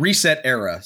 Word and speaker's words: ResetEra: The new site was called Reset Era, spelled Reset ERA. – Pronounced Resetera. – It's mostly pronounced Resetera as far as I can tell.